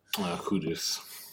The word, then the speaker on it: Kudus: Every time I've said it, I've said Kudus before. I've said Kudus, you know, and I've been corrected by our Ghanaian listeners in the comment section berating me for my pronunciation, which they are absolutely within their rights to do.